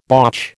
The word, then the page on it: botch: American English